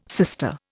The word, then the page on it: sister: Indian